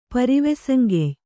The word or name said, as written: ಪರಿವೆಸಂಗೆಯ್